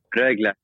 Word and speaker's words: Rögle: Rögle.